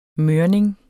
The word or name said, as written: mørning